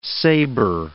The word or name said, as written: saber